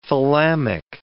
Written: thalamic

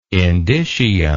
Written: indicium

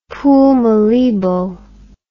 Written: Pool Malebo

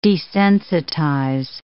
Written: desensitize

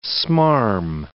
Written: smarm

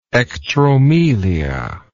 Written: ectromelic

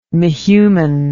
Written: mehuman

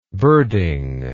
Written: birding

